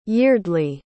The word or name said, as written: yeardleigh